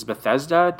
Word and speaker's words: Bethesda: So like, I just wouldn't be surprised that now that Microsoft owns Bethesda, did I say that right? Isn't that right? – Yes, you got that correct.